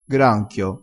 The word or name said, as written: granchio